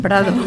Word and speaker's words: Prado: Como os decíamos hace unos instantes, vamos a acercarnos hacia el Museo del Prado, del Pardo. – Del Prado. – Lo he dicho bien, del Prado.